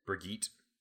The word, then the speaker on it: Birgit: And then the first name is B-I-R-G-I-T. – Brigitte.